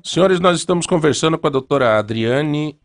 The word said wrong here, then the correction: Adriana: é, Adriane, né? – Adriana. – Adriana. Uhum. Eu sabia que nós estávamos falando errado.